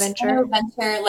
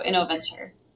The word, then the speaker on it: InnoVenture: But currently you're the investment director at, is it InnoVenture? Am I saying that correctly? – Correct. InnoVenture, like the combination of two words, innovation and venture capital. So InnoVenture.